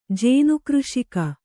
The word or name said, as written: ಜೇನುಕೃಷಿಕ